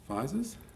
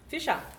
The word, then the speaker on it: Fizers: I'm here with Ben Hunter to talk about the little book of big weight loss with Bernadette Fizers. – Fisher. It's Latvian. – Fisher. – It's Latvian. – There's no H in there. – It's Latvian. No, there's no H on there, but it's pronounced Fisher.